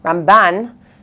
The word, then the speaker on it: Ramban: So the way that you can tell the difference is Rambam, the accent, is on the first syllable. Ramban, the accent, is on the second syllable.